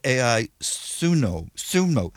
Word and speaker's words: Ai Tsuno: And I was quite surprised when I first heard of AI Tsuno, how do you pronounce that? – Ai Tsuno, yeah. – Ai Tsuno. – I call her Ai Tsuno. It's a Japanese woman's name. Ai is a common first name in Japan.